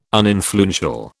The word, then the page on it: uninfluential: British English